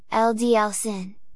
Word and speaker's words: Auld Lang Syne: Auld Lang Syne, Scots pronunciation, LDL Sin, note S rather than Z,